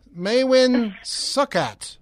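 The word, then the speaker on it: Maewyn Succat: Maywin Suckus? – You're close. – Maywin. – Yeah, you're close enough. Maywin Suckat. It's spelled Maewyn, second word, Suckat.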